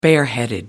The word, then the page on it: bareheaded: American English